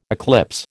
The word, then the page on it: eclipse: American English